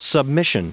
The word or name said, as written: submission